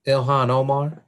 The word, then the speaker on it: Ilhan Omar: Yeah, it, you know, um, in response to that, it's like uh, Omar, uh, uh, what's her name? Ilan Omar, one of the um Ilhan Omar. – Yeah, yeah. I just want to pronounce her name right.